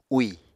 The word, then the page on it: úì: Apatani